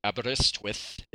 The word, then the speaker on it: Aberystwyth: Aberystwyth is the pronunciation. – Ah, very nice. – Aberystwyth.